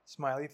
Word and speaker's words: Ismaili: Sorry if I said that wrong. No. Ismaili faith.